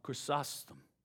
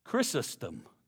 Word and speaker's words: Chrysostom: Chrysostom. Chrysostom. I've never been able to pronounce that the, pronounce that the first time. Chrysostom. I'll get it right. I put the syllables in the wrong place, is what happens.